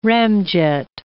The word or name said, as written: ramjet